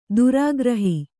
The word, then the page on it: ಧಾರಾಗೃಹ: dhārāgřha